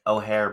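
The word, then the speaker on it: Eochaí: And O'Hare, by the way, is spelled E-O-C-H-A-I.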